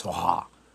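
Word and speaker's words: Svaha: And finally, Swaha, pronounced Swaha in the Tibetan.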